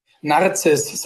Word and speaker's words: Narziss: Narziss, so wie die Narzisse, die Blume.